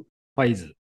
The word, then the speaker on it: 555: ファイズ。